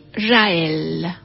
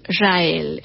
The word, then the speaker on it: Jaëll: que es Mari Rael. Mari Rael. El apellido, les cuento que se escribe con J, J, A, e, doble L y la E con diéresis, Marie Rael, les decía, fue una destacada pianista, compositora y pedagoga que nació un 17 de agosto, pero del año 1846.